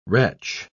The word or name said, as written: retch